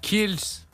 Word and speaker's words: Kielce: Kielce. Voilà, l'orthographe. – Tu sais où c'est? – Kielce. – C'est près de chez vous, en fait. – Kielce.